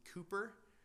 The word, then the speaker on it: Cowper: a poet by the name of William Cooper. It looks like it should be pronounced Cowper.